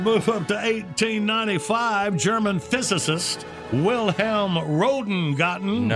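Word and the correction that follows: Röntgen: Well, Rentgen is how you pronounce that, believe it or not.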